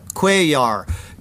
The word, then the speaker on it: Cuellar: And then from the Gateway Pundit, Democrat Representative Henry, I don't know how you pronounce his name, Cuellar? – Cuellar.